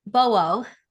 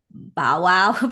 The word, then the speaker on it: Bowo: And I know a lot of people call it Bow Wow because I think when you first see the name, it's easy to pronounce it that way. But it's actually yes. a short form of Boss Women.